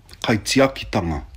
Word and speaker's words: Kaitiakitanga: Kaitiakitanga. Oder ganz richtig ausgesprochen. – Kaitiakitanga. – Ja, so.